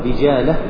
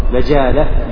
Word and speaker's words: بجالة: هذا غلط جزما، يقول بجالة بكسر الموحدة والجيم المعجمة، كيف بكسر الجيم؟ لا يمكن نطق بها، وإنما هي بفتح الباء والجيم، فغلط من الطابع. بجالة بفتح الموحدة والجيم المعجمة.